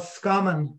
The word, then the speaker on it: Roscommon: But my funny expression for Roscommon is that the people of Roscommon have a very strange pronunciation of Roscommon. They tend to drop the R and the O, and it's called Scommon.